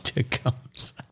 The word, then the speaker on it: Tecumseh: Tecumseh.